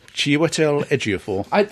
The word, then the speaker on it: Chiwetel Ejiofor: Serenity actor, and much more besides, including 12 Years a Slave, Chiwetel Ejiofor, has also been awarded a CBE. – Mm. Say that again. – Chiwetel Ejiofor. – That's the first time I've actually heard it pronounced correctly.